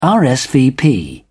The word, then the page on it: rsvp: British English